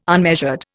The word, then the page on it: unmeasured: Indian